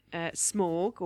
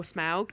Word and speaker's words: Smaug: There'll be a dragon, Smaug or Smaug, depending on how you want to pronounce it, who I believe is going to be voiced by Benedict Cumberbatch, which is quite exciting.